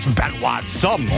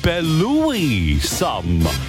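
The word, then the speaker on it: Belouis Some: Der heißt einfach nicht Benoit Somme oder sowas. Nein, es ist eigentlich ausgesprochen Belouis Somme. So wie Brother Louis. Belouis Somme.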